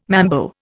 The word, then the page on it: mambo: Indian